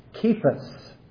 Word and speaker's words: Kephas: You shall be called Kephas.'" And that's right, it's pronounced with a K sound.